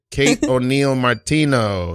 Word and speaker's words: Kate O'Neil Martino: And our guest tonight is the great, the prolific Kate O'Neil Martino.